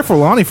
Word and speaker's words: Forlani: Claire Forlani? – That's how you pronounce her name. Yeah. – Claire Forlani, for real.